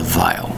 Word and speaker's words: vial: That is called a vial. V-I-A-L. Believe it or not, that is two syllables. V-I-A-L. Vial.